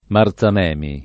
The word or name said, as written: Marzamemi